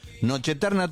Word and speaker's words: Noche Eterna: Noche Eterna todo junto, no separado como se escribe, digamos.